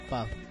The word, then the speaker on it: Pugh: yo estoy leyendo así, pero no sé si está bien pronunciado. – es Pugh.